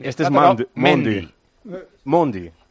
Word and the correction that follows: Mendy: Pero si es con E, Mendy. – Pero en francés se pronuncia Mendy.